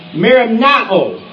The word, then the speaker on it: merimnao: Now, the Greek word for worry used in this passage is merimnao. Merimnao is spelled M-E-R-I-M-N-A-O.